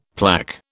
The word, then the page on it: plaque: British English